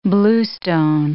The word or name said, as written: bluestone